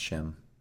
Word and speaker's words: Sim: It's spelled Sim, but pronounced Shim. – S-I-M? – Correct.